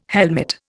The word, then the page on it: helmet: Indian